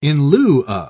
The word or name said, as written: in lieu of